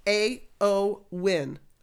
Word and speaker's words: Eowyn: Well, if I had gone to her website, the first thing she says is Eowyn, pronounced A-O-Win.